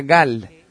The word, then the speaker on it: Gal: Pero lo importante aquí es que el número 33 está escrito como GL en hebreo y se pronuncia Gal.